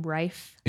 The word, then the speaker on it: Rife: Is that how you say his name? Rife? – Yeah, R-I-F-E.